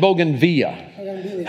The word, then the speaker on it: bougainvillea: How many said bougainvillea? How many said bougainvillea? How do you say it? – Bougainvillea. – And he's from south of the border, so I'm going to say it his way because that's where it comes from.